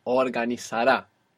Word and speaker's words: Organizará: Organizará. So the useful thing about the accent being on this ending is that we don't have to worry about where the accent goes when we're pronouncing the whole verb.